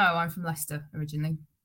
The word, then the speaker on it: Leicester: And and I know not to pronounce it Leicester, a Leicester or whatever. You know, we know Leicester.